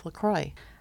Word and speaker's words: LaCroix: It's LaCroix.